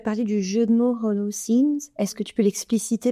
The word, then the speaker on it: Holocène: Donc on peut le prononcer pour les Français Holocène.